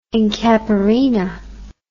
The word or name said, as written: incaparina